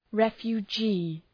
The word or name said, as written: refugee